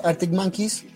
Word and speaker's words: Arctic Monkeys: Yo la única banda que conozco del indie son los Arctic Monkeys, que no sé si lo pronuncio bien, sí, sí.